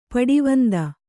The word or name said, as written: ಪಡವಂದ